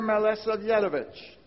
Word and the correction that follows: Milosavljevic: And I mispronounced that last name, and I'm sorry. Milosavljevic, great distinction.